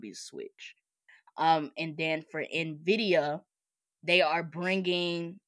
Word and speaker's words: Nvidia: Keep going, Envia. – Nah, it's Nvidia. That's how you say it.